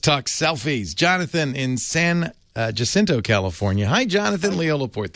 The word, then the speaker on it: San Jacinto: I pronounce it Jacinto, but what do I know? – Now, of course, the Spanish pronunciation would be San Jacinto, but I don't.